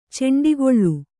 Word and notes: ಚೆಂಡಿಗೊಳ್ಳು: kannada; ceṇdigoḷḷu

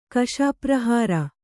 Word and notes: ಕಶಾಪ್ರಹಾರ: Kannada

